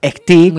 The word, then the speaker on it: Khtek: Vamos con Houda Abous, también conocida como Ektik. Ektik. – Muy bien pronunciado.